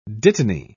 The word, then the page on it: dittany: British English